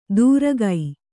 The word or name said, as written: ದೂರಗೈ